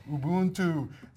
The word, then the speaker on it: Ubuntu: So we had an Ubuntu or Ubuntu. Hanselman clubs me over the head every time I say it wrong. Ubuntu.